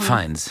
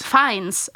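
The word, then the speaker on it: Fiennes: In Harry Potter ist das, das ist, ist das der Voldemort auch der Schauspieler, wie, wie wird sein Nachname ausgesprochen? – Feins. – Feins. – Aber das, das ist Ralph Feins.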